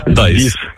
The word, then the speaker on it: dies: Так, тільки англійською дієс, як читається. От пишеться D-I-E-S. Дайс.